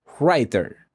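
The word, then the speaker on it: Rytr: Esta inteligencia artificial se llama Writer, pero no como se escucha. Que tú dirías, ah, escritor. No, se escribe R-Y-T-R. R-I-T-R. R-I-T-R. Writer. Writer. Algo así.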